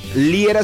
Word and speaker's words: Liera: Búscanos como Amor FM y a tu servidor por mi nombre, Fernando Liera, se escribe L-I-E-R-A.